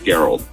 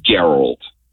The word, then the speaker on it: Gerald: It was Gerald. It's spelled like Gerald, but it's Gerald. – Wait, did your parents call you Gerald? – Oh, my God. So it's like Harold with a G.